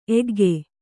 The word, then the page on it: ಏಕೈಕ: kannada